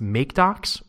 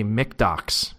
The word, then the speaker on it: MkDocs: So I picked a separate one, and this one is either pronounced MakeDocs or MKDocs. I actually just gave a talk on static site generators at San Francisco Python, and I tweeted at Tom Christie, who's the creator of not only Django REST framework, but this project as well, MakeDocs. And I said, hey, I'm going to check myself before I wreck myself. How do I pronounce this project? And he said, well, you know, I say MakeDocs with a Scottish accent.